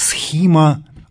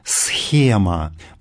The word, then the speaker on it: схима: Что касается греческого слова, которое в качестве церковного термина произносится как «схима», оно еще существует в русском языке в виде слова «схема», потому что «схема» — его античное произношение.